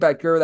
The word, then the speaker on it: Boettger: And to be able to follow Eli on Twitter/X, that is at his last name, Becker, that is spelled B-O-E-T-T-G-E-R underscore, and then Eli, and then for E-Check CBB, easy enough, at E-Check CBB all together.